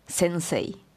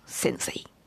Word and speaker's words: sensei: And in Japanese pronunciation, it is sensei, not sensei.